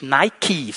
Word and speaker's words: Nike: Wenn ihr nämlich so einen Sneaker habt mit einem Swash drauf und unten steht Nike, so wird das ja heute ausgesprochen auf Neudeutsch, ist es genau dieses Wort Nike ist ein griechisches Wort.